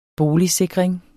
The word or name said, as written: boligsikring